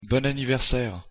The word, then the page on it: bon anniversaire: French